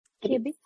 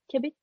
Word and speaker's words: Kebitz: hur uttalar man det? – Kebits. – Vad heter det? Kebits. Ja. – Kebits, e K-E-B, eller? – Ja. Ja, K-E-B-I-T-Z faktiskt.